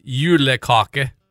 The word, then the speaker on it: julekake: It's pronounced julekake.